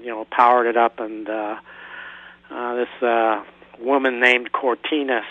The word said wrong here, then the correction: Cortana: starts asking me all these questions. – Cortana. Yes. – Yeah. I'm sorry, I mispronounced.